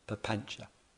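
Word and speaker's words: papañca: And the word for this activity that goes on, all this mental activity and proliferation, is called papancha, P-A-P-A-N-C-A, pronounced papancha.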